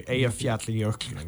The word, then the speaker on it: Eyjafjallajökull: So, Eyjafjallajökull.